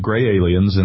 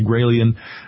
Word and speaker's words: Graelian: there are so many folks who say gray alien, they can't pronounce Graelian, but yes, it is Graelian report.